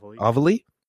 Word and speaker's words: Avalee: Like, I've talked about all of oh Lord, how do you say her name? I do not want to mess it up. – Avalee, did I say it right? Okay, awesome. Yeah, I love her so much, and I didn't know who she was until G4.